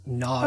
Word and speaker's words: Gnog: I guess it's just pronounced Nog.